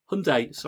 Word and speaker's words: Hyundai: I've got to pronounce this properly, boys, because I had to get mine serviced, and they said it's not Hyundai, it's Hyundai, or Hyundai. Hyundai, very good.